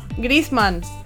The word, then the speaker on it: Griezmann: Está Son Heung-min o Griezmann se escribe. ¿Cómo se pronuncia? Griezmann.